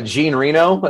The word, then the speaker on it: Jean Reno: Jean Reno, as we say in America.